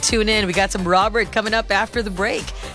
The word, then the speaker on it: Rol3ert: You know, interestingly, his name is spelled as R-O-L-3-E-R-T, but it's really just Robert, okay? He's just writing it in a funky way.